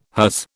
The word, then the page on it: hers: British English